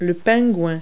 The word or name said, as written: penguin